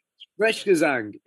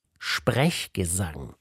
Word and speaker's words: Sprechgesang: Sprechgang. How do you say? Sprechgesang. Yeah, yeah. – Sprechgesang. Schwieriges Wort.